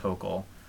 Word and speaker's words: Hochul: I was wrong. It is pronounced Hochul.